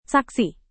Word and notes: saksi: indonesian

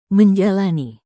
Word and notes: menjalani: Indonesian

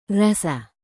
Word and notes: rasa: indonesian

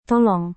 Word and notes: tolong: Indonesian

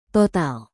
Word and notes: total: Indonesian

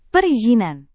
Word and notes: perizinan: Indonesian

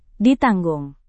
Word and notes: ditanggung: Indonesian

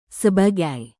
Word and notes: sebagai: indonesian

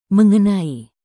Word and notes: mengenai: indonesian

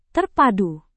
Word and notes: terpadu: indonesian

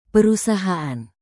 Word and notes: perusahaan: indonesian

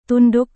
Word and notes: tunduk: Indonesian

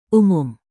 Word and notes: umum: indonesian

